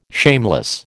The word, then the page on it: shameless: American English